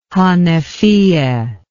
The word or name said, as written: hanefiyeh